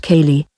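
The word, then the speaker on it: céilí: Nowadays, if you want to listen to real Irish music, go to a Cailí, pronounced Cailí, a folk dance get together where you can also listen to traditional Irish instruments like the fiddle.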